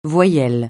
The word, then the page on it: voyelle: francais